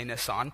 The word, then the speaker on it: Nisan: It's spelled Nisan, but I feel more scholarly if I say Nisan.